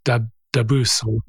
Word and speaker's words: DABUS: D-A-B-U-S, Dabus.